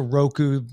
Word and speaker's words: Roku: And also Roku rhymes with Coke.